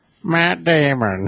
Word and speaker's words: Matt Damon: Matt Damon. – Wait, you didn't say that right. Say it again. – Matt Damon.